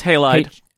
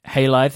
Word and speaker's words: Halide: There is an application called, I'm going to say it's Halide. I don't know how you pronounce this. – Halide. – Halide, thank you so much. H-A-L-I-D-E.